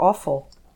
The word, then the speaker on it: offal: Let's go back to the 1900s of eating more offal. – Well, it sounds awful, but it's spelled O-F-F-A-L.